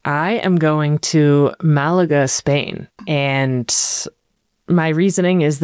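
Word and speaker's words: Málaga: According to Google's pronunciation guide, it's Malaga.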